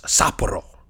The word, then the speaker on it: Sapporo: Sapporo, it's actually pronounced Sapporo.